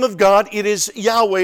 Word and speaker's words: Yahweh: Now, no one's exactly sure how it's pronounced because the Old Testament was written without vowels. But I think scholars are pretty much set that the best pronunciation is Yahweh. And I think some of your Bible translations may do it Jehovah. And that's fine, but I think it's best that it is Yahweh.